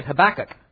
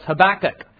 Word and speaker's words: Habakkuk: Because up until I heard Monik preach it last week, I was saying Habakkuk, because that's kind of like the English way of saying it. You stress the second to the last syllable. In fact, who's that guy with Goliath in the sling? What was his name? David. But it's actually pronounced David. But no one thinks King David, it's King David. So it's Habakkuk.